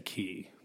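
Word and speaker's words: Cay: And it's spelled C-A-Y-K, but they say Key. That's how they pronounce it.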